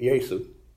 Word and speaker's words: Jesu: You translate that into Latin, you get Jesu, because in Latin, J is pronounced like a Y.